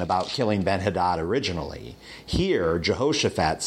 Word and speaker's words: Jehoshaphat: And so he asks for Jehoshaphat, would be perhaps a better way to pronounce that, for his help going up against Ben-Hadad.